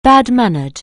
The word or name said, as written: bad-mannered